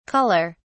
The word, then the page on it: culler: British English